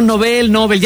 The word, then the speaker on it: Nobel: Porque creo que es Nobel, ¿no? La pronunciación correcta. – Nobel, en efecto.